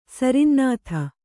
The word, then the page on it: ಸರಿನ್ನಾಥ: kannada